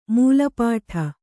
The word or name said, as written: ಮೆಲ್ಪಾಡು